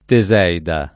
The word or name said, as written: Teseida